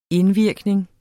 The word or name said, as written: indvirkning